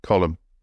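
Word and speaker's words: Colm: I probably pronounced that wrong Irish name. C O L E B. Column. Fuck me, really? Yeah. me see you out. Not column like Nelson's, column that's what I said.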